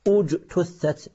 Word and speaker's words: اجتثت: نعم، لاحظوا مثلا في قوله تعالى ومثل كلمة خبيثة كشجرة خبيثة اجتثت، عند الدرج تقول خبيثة اجتثت، عند الوصل لا بد أن تنطق همزة الوصل هذه همزة قطع مضمومة، اجتثت من فوق الأرض.